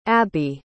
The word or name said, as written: Abbie